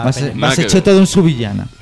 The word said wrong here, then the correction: Subijana: Subijana. ¿Cómo se dice? Subijana.